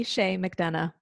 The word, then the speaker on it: Chaix McDonough: So my name is Lee Chaix McDonough, but you're welcome to just call me Lee.